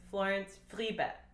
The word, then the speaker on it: Florence Friebe: So the real way to say my name is Florence Friebe. And that is very German. So if you can go... Then you can slide from the F to the R that way, you're golden.